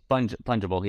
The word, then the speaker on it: fungible: Did I say that right? – Fungible, yeah. – Fungible. Sounds like fungus.